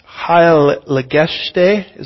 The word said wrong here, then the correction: Heilsgeschichte: Is that how you say that? What is it? Thank you. Heilsgeschichte. Thank you so much.